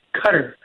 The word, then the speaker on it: Qatar: Just to let you all know, the pronunciation of the country is Qatar. Pretend like you're thinking of a C and a Q at the same time, and you're saying Qatar.